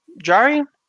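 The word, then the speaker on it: Jarry: But yeah, also this is a team that's very solid defensively, and they have a great goalie in Tristan, is it Jari? – Jari. – Yeah. It starts with a J, but from BC, so it's just like, oh, maybe it's pronounced like a J, as opposed to the soft J.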